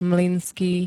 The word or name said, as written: MLYNSKÝ